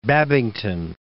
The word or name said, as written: Babington